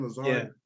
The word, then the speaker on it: Lazard: You know, Lazard, is that how you pronounce his name? Lazard.